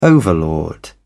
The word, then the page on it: overlord: British English